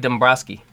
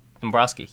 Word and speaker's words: Dombrowski: Dave Dombrowski. Dombrowski, yeah.